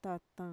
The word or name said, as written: tatan